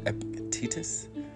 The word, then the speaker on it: Epictetus: E-P-I-C-T-E-T-U-S.